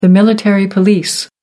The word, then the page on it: military police: American English